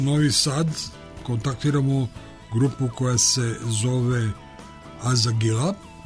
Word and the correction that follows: Azagile: ako sam dobro izgovorio. Dobro veče. – Dobro veče. – Jesam dobro izgovorio ili se tu drugačije izgovara? – Izgovara se zapravo Azagile.